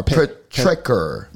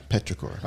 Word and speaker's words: Petrichor: How do you pronounce that? P-E-T-R-I-C-H-O-R. Petrichor. – Petrichor. – All right, Petrichor.